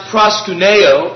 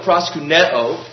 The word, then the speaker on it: proskuneo: Technically, it's pronounced proskuneo, proskuneo.